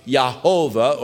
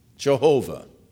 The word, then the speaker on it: Jehovah: There have been those that have guessed that the first vowel following the Y was an A, that the vowel following the V, or following the H, was an O, and following the V was another A, and so you have the pronunciation of Yehovah, or the hard J in our English, Jehovah.